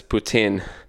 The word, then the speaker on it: poutine: He pronounces poutine as poutine. And everyone gives him, yeah. Everyone gives him grief for it, but I guess that is how it's pronounced.